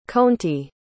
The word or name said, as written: conti